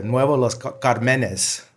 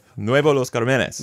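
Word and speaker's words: Nuevo Los Cármenes: Nuevo Los Carmenes. – There you go. – Los Carmenes. Yeah. Because there's an accent on it.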